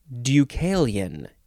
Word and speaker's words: Deucalion: This is Deucalion. Deucalion. It is spelled capital D-E-U-C-A-L-I-O-N.